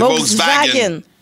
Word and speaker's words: Volkswagen: C'est Volkswagen. – Volkswagen, oui, c'est ça. – Ouais, le W en allemand, tu le prononces comme un V.